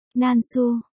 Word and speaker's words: Nam Thư: Nam Thư, Nan Su, và khó thua, Nan Su, đều có phát âm là Nán Su.